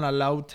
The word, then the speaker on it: elote: So what I'm talking about is called, and I'm going to say this wrong, elote? – What? – That sounds right. – It's E-L-O-T-E. – It sounded good.